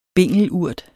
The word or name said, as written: bingelurt